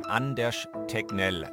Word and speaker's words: Anders Tegnell: Anders Tegnell ausgesprochen. Also ein härteres G und dann ein Tegnell und Anders der Vorname.